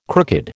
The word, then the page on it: crooked: American English